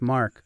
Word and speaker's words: Marc: What if like everywhere you went, people were like, Merce? And you were like, Mark. What? Merch. Mars. No, it's Mark. It's just how it's spelled.